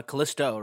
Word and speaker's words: Callisto: Callisto, right? – Yes, I'm saying it right.